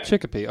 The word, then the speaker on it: Chicopee: So it's Chicopee? Is that how you say it? – Yeah. – Chicopee, okay.